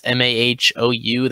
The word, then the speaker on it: Mahou: I think it's pronounced like Mahou or something. It's Mahou.